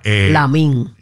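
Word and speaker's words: Lamine: Oye, hablando de perruchos y perruchas, señores, ustedes saben que está bien pegado Yamin Lamal. ¿Verdad? – Lamín.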